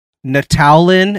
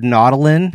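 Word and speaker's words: Nautolan: Natalin instead of Nautilin.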